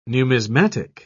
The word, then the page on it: numismatic: British English